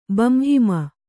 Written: ಬಂಹಿಮ

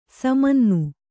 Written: ಸಮನ್ನು

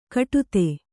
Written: ಕಟುತೆ

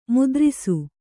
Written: ಮುದ್ರಸ

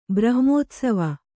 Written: ಬ್ರಹ್ಮೋತ್ಸವ

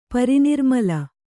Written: ಪರಿನಿರ್ಮಲ

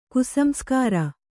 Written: ಕುಸಂಸ್ಕಾರ

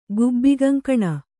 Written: ಗುಬ್ಬಿಗಂಕಣ

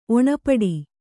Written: ಒಣಪಡಿ

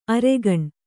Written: ಅರಗಿಣಿ

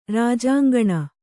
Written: ರಾಜಾಂಗಣ

